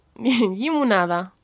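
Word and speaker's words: llimonada: Lemonade right, was again, really difficult to pronounce. It was... Yemonda? – Yimunada. – Yimunanda. – You always put an N in there. Yimunanda. Yimunada. – Yimunanda. – Yimunada, yeah.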